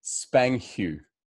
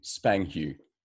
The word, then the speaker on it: spanghew: The next word, this is amazing, is the word spanghu. I'll spell it S-P-A-N-G-H-E-W, spanghu.